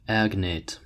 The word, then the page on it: agnate: American English